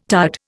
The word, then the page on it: tired: Indian